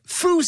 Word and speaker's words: Foo: I call it a little game of Foo Said That. Get it? Because Foo rhymes with who.